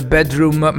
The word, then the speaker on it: bdrmm: Bedroom, attenzione, non si scrive come io lo pronuncio, cioè bedroom, ma dovete levare le vocali. Quindi loro hanno voluto scrivere il nome come band con BDR e WM, e che però lo leggi bedroom, ma eh, si scrive BDRMM.